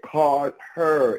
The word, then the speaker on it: Cardher: And just to be clear, Carter, it's spelled C-A-R-D-H-E-R, if you guys understand how that goes.